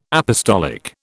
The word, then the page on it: apostolic: British English